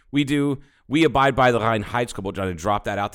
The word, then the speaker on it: Reinheitsgebot: Reinheitsgebot, but it's Reinheitsgebot. Like I fucking love saying that, because if you say it the proper way, a German will